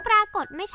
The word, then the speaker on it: ปรากฏ: นั่นสิคะคำนี้อ่านว่าปรากฏไม่ใช่หรือคะเสียงสะกดเป็นดอเด็กชัดๆเลยทั้งดอชดาและตอปตะเมื่อเป็นตัวสะกดออกเสียงเป็นดอเด็กด้วยกันทั้งคู่คือเป็นตัวสะกดในแม่กฎไงจ๊ะ